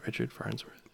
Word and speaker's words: Farnsworth: Richard Farnsworth.